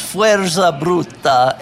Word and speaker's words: Fuerza Bruta: Yeah, let me help with the pronunciation. It's actually might be Spanish. It's Fuerza Bruta, and it means brute force in Spanish, which is indicative of one of the many problems that this show had.